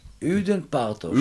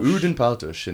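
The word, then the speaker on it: Ödön Pártos: Amongst his teachers were Odin Partosh. Did I say that correctly? Odin Partosh. – Udin Partosh. – Udin Partosh. In English, it doesn't make any sense.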